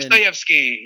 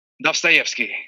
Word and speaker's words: Dostoevsky: Dostoevsky. – Okay. Say it one more time for me. – Dostoevsky.